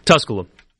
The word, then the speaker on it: Tusculum: Yeah, they're playing Tusculum? I said it right. Tusculum? – Tusculum.